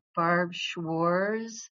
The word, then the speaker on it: Barb Schwarz: There's no T in the name, so Barb Schwarz, it rhymes with s'mores. And so, which I used to eat around the campfire.